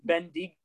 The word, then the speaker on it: Bendigo: Okay, so it's not Bendigo. It's Bendigo. Fine, Bendigo.